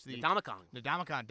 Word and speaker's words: Ndamukong: Ndamukong.